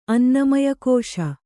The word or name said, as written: ಅನ್ನಮಯಕೋಶ